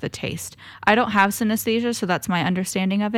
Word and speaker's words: synesthesia: And then the last session we did was synesthesia painting.